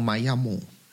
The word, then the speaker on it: Miami: Es el Miami, que se pronuncia aquí algo así como Miami.